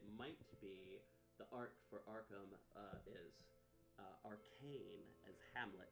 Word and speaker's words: Arkham: Arcane, but it's Ark, it's a Hamlet, so Arkham.